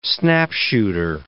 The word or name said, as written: snapshooter